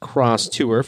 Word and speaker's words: X-Tour: But I'm talking about the Watt Wagons Cross Tour, and it's spelled X-Tour, but it is pronounced Cross Tour for anybody wondering who's been looking at the website.